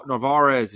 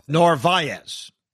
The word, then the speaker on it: Narvaez: The reality is Narvaez is not – I'm saying I'm saying it wrong. – Narvaez. – There you go.